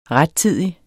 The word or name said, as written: rettidig